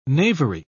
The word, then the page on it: knavery: British English